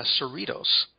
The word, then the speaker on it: Cerritos: It's Cerritos.